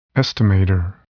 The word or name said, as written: estimator